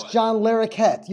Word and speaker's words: John Larroquette: I cannot pronounce his last name, but it is John Larroquette, something like that. – You, really? – What? – That's John Larroquette.